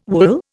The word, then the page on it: wool: Indian